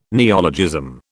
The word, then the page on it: neologism: British English